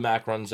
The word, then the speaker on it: macarons: I'll give it 3.4 blue macarons out of 5. – All right. I love how you pronounce macaroons there. Macarons. – I went French. – Yeah, that's the French way.